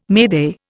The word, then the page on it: mayday: Indian